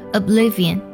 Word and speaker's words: oblivion: and thinking good oblivion，这个单词有点难读啊，它读作 oblivion，它拼作 o b l y v i o n，oblivion，它指的是淹没啊，或者说是无意识状态的意思，而 think 指的是呢，呃，沉默的意思。